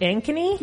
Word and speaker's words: Ankeny: Now, is it Ankeny? – Yes.